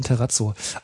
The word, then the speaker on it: Terrazzo: Wahrscheinlich wird das auch ganz anders ausgesprochen, so wie Terrazzo. – Nee, nee, ist schon Terrazzo.